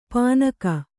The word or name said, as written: ಪೆಣ್ಣುಗ